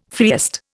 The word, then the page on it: freest: Indian